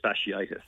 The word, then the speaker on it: fasciitis: Plantar fasciitis.